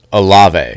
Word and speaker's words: Olave: It takes that the Ohio State receiver's name is pronounced Olave.